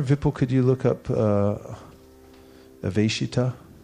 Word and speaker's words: Aveshita: Aveshita, that's a long A. V-E-S, like S-H, slash over it. ITA, Aveshita. Therefore,